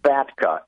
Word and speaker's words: FATCA: FATCA.